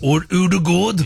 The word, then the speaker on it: Ødegaard: At the end of the game, after they won, the captain, Martin Ødegaard, how would you pronounce that? Martin Ødegaard. – That sounds Ødegaard. Pretty Vikingish. – That's how you're supposed to say it. – Ødegaard.